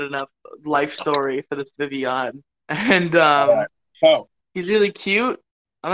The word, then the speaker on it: Vivillon: Vivillon, I believe, is the actual pronunciation. I've always said Vivillon, but I think it's Vivillon.